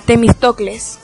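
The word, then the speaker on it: Temístocles: No, eso no es difícil, mira, Temistocles.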